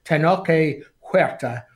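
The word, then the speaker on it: Tenoch Huerta: played by Tenoche Huerta. – Oh, listen, full marks for pronunciation there.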